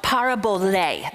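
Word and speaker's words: parabole (παραβολή): So Greek, it's called parabole. That's the way it's pronounced.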